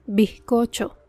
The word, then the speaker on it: bizcocho: Pero ¿cómo pronunciamos la Z si le sigue una consonante? Por ejemplo, bizcocho, hallazgo, liderazgo. Puedes notar que reducimos la intensidad de la letra Z. Suena como un leve suspiro.